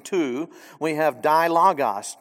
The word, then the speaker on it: logos: And if we were to take the original Greek word, perhaps many of us have already heard the Greek word logos or logos, depending on how you pronounce it. Logos, proper pronunciation.